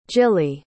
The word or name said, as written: jilly